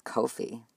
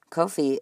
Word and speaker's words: Ko-fi: I had to look up how to pronounce that word, and yeah, apparently it's Ko-fi. Ko-fi is a website that allows supporters to offer a one-time donation.